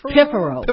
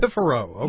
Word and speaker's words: Piffaro: Now, the next one coming up, I'm going to ask you to pronounce this because I'm having troubles with is it Piffaro? – It's Piffaro. – Piffaro. Okay.